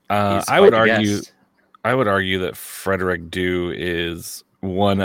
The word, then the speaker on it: Due: And if you are a USL broadcaster, that is how you pronounce his last name. It's Due.